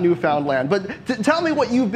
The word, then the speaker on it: Newfoundland: Am I pronouncing that right, Deirdre? Newfoundland? That's what I was taught, but I'm American. You're Canadian. – Newfoundland. – You can tell me these things. Newfoundland? Okay, good.